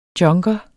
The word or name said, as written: junker